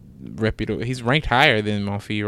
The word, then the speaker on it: Monfils: I think Monfils.